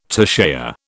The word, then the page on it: toucher: British English